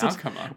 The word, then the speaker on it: nakama: nakama, I suppose you pronounce nakama.